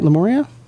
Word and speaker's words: Lemuria: Am I saying that right, Lemuria? – Yeah, yeah. I think they like the fact that people have a hard time pronouncing their name, but I'm pretty sure that's it.